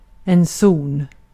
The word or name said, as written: zon